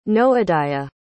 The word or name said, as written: noadiah